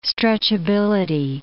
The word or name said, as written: stretchability